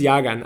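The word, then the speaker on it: Llagan: Und dann habe ich es einfach klar, weil der Typ ist von den Philippinen und in den Philippinen spricht man es natürlich wie im Spanischen, mhm. das Doppel-L wie ein J, deswegen Lawrence Jagan.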